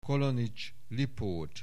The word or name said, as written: Kollonich, Lipót